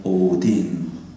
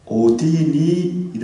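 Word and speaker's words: 오딘 (ὠδίν): 해산의 고통을 어떻게 발음한다고요? 오딘. 뒤에 액센트가 있습니다. 오딘. 임신한 여자에게 오딘이 이름과 같이 제가 지금 강조하는 이유가 있습니다.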